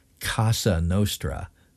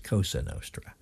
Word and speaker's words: Cosa Nostra: But the FBI was careful to characterize this as an operation of what Kash Patel hilariously mispronounced as the Casa Nostra when he meant Cosa Nostra, placing the spotlight on Italian-American organized crime families.